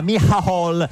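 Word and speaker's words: Micheál: Perché essendo irlandese uno pensa che si pronuncia all'inglese, si chiama invece, c'è scritto tipo Martin, invece si chiama Michal Martin, tipo alla toscana un po', però insomma.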